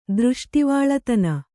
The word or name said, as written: ದೃಷ್ಟಿವಾಳತನ